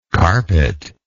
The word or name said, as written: carpetlike